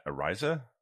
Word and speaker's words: Araiza: Next one I think we should touch on is Matt Araiza.